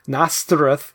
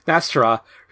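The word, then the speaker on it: Nastirh: But Pryor's own latent mutant powers were now beginning to emerge, and eventually the demon, Nastirth, I think I said that right, it's N'*ASTIRH, or wait, I said it with an th when it should be a huh. Or whatever, Nastira, released both her powers and the dark side of her personality.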